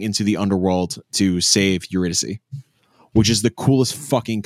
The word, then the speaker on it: Eurydice: So when we were talking about it 3 years ago, what this is, the narrative is it's the adaptation of Orpheus and I can never pronounce her name. You're Dicey. – You're close. – Yeah. Oh. – I know, but this is getting dicey. – That's them. Eurydice. I just did a Google pronunciation thing. Eurydice. – Wasn't even close.